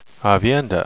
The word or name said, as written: aviendha